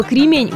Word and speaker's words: кремень: При склонении слова «кремень» ударение переходит на окончание слова, а значит будет «кремень», «кремни», «кремню», «кремням», «о кремнях», ну и так далее. Но при любом из вариантов никогда и нигде ударение не попадает на первый слог. В качестве подсказки можно использовать слово «ремень». Ремень обычно не вызывает вопросов, а ударение ставится совершенно аналогично.